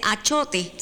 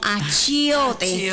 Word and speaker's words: achiote: Y pues hablando de la chiote, como me corrigió doña Maribel, no se dice achote, sino achiote.